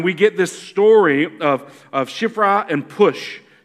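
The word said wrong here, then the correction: Puah: Shifra and Pua, I'm sorry.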